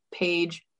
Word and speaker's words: Paige: It is Courtney Paige Nelson. Paige is spelled P-A-I-G-E, not like the page in a book.